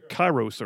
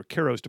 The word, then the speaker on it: Kairos: One was Chronos, which is Greek for basically just measuring time, chronological, and then the other one was Kairos or Kairos, depending on how you pronounce it, K-A-I-R-O-S, and that basically means a moment in time where you have an opportunity to act.